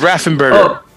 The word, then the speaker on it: Raffensperger: He kept saying Raffenpecker, but it's Raffenberger.